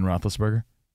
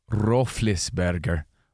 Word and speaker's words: Roethlisberger: And he said, did you know Ben Roethlisberger's name isn't pronounced Ben Roethlisberger? No, I'm not even joking. According to this link he sent, it's Ben Roethlisberger. Roethlisberger.